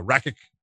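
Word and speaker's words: Rakić: I always say it right. Rakic is the way I say it.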